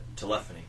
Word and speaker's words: Telephony: Telephony.